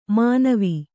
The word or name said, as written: ಮನವೆ